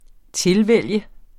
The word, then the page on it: tilvælge: -ˌvεlˀjə